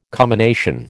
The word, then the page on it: commination: American English